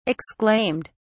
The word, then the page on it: exclaimed: British English